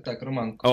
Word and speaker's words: Романко: Так, Романко.